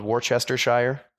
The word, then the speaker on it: Worcestershire: It's sounds like a, look, it'd be a town in England spelled Worcestershire. – Oh, Worcestershire? – Yeah. – Worcestershire? – How do you pronounce that word? Worcester? – Worcestershire? – Worcestershire sauce? You don't pronounce all the syllables.